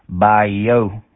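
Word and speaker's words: Bayeux: Bye-yo. How copy? – I copy fine. That would be pronounced in French, bye-yo.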